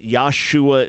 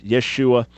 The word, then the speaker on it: Yeshua: We dismiss the idea that Yahshua is the correct pronunciation. Of course, it's Yeshua.